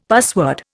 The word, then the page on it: password: Indian